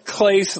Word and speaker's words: Clace: No, he does pronounce it Clace like grace.